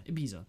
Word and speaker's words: Ibiza: Is that how you pronounce it? Ibiza? – Yeah. – Yeah, Ibiza.